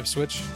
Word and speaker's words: Ipswich: Ipswich.